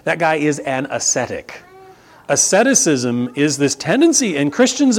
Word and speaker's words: asceticism: Well, look, if your physical body doesn't matter, then you quickly fall into what's called asceticism. Okay? Look at that word going, how do I pronounce that? Gabriel, that is asceticism.